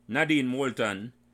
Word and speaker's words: Nadine Moulton: The first guest today is Nadine Moulton, and I hope I pronounced your name correctly, Nadine.